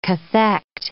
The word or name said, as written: cathect